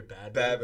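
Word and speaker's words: Bhad Bhabie: Is that how her name is pronounced? Bod baby or bad baby? – Bad, oh yeah.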